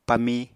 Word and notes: pamí: Apatani

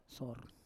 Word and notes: sor: Roon

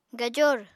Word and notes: gajor: apatani

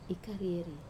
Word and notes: karieri: wamesa

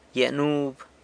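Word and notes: gyia nub: San Lucas Quiaviní Zapotec

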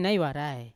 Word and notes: nai warai: Yawa

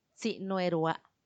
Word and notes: tsinuerua: Macuiltianguis

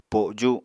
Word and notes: poju: Apatani

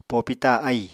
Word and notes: popita ahì: Apatani

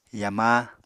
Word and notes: yama: Apatani

